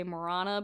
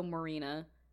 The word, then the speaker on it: Morana: It's either, you can pronounce it more, it's spelled Morana, so you could say Morana, but it's also Marina. It's interesting. It's like interchangeable.